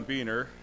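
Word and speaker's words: Boehner: My name is John Beener. It is spelled the same as that guy that was in Congress, but we're not related, so that's that.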